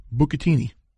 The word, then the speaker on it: bucatini: Bucatini.